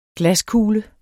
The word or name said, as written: glaskugle